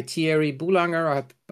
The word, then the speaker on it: Thierry Boulanger: It was founded in 2016 by Thierry Boulanger. I pronounced that correctly.